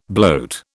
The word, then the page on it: bloat: British English